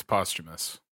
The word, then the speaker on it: posthumous: Google pronunciation just hit me with it. It's posthumous.